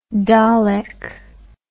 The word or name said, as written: Dalek